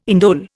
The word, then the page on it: indole: Indian